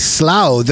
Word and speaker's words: Slough: It's Slough, the name of the town where The Office, the UK comedy, is based. But I've spoken to Moitra about this. He had no awareness of the place Slough.